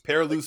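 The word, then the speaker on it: Perrilloux: Perilou spelled with an X, right? – Yep. Yeah. Bayou Perilou type of thing.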